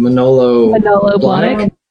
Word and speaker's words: Manolo Blahnik: Manolo Blahnik.